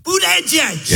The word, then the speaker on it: Buttigieg: Boot-edge-edge. – That's how you pronounce it. – Boot-edge-edge.